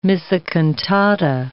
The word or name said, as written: missa cantata